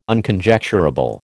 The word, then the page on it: Unconjecturable: American English